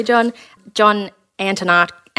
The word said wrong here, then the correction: Antonakis: Antonakis, sorry, um, insert really good pronunciation there, um, from Switzerland.